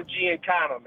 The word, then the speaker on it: Giancana: It's Sam Giancana, man.